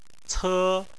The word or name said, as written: ce1